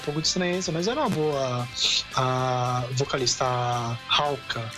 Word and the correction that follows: Haruka: Hauka é Haruka.